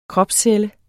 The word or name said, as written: kropscelle